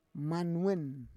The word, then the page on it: manwen: Roon